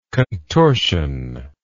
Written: contortioned

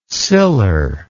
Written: siller

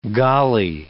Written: golly

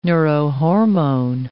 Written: neurohormone

